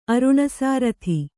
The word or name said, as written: ಅರುಣಸಾರಥಿ